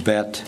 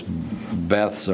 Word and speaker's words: Beth: Now Joshua sent men from Jericho to Ai, which is beside Beth-Aven, pronounced Bet if you go to Israel. Those Beths are pronounced Bet, Beth-Aven, on the east side of Bethel, and spoke to them, saying, go up and spy out the country.